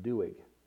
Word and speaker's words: Doeg: and his name was Doeg. It's actually pronounced Doeg.